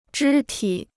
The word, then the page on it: 肢体: zhī tǐ